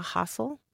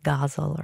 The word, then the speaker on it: ghazal: When I'm working with my students on this forum, we practice that sound at the beginning, hustle, because often they'll say ghazal or ghazal.